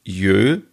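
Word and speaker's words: jól: Weihnachten heißt auf Isländisch wohl Jö. Ja, also so wird es, hat Siri mir das ausgesprochen.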